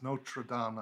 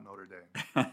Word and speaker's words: Notre Dame: It's Notre Dame, not Notre Dame.